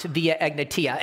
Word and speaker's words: Via Egnatia: And the reason why I really put that slide back up there is because I finally figured out how to pronounce Via Egnatia.